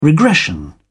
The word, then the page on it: regression: British English